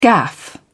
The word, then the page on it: gaffe: British English